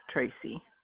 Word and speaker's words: Tracy: Monzon, M-O-N-Z-O-N. – M-O-N-Z-O-N. – Yes, Tracy. – And Tracy is spelled? – T-R-A-C-Y.